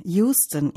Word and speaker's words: Houston: Nebenbei bemerkt, die Straße wird wie Houston in Texas geschrieben, aber tatsächlich als Houston ausgesprochen.